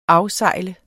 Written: afsejle

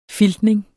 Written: filtning

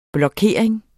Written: blokering

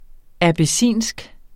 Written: abessinsk

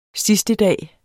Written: sidstedag

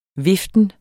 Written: viften

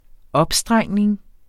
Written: opstrengning

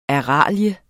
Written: ARALIEN

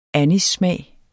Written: anissmag

